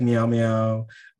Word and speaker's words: Meow Meow: Like Meow Meow was great. I pronounced that right? Meow. – You did great.